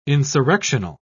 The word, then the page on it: insurrectional: British English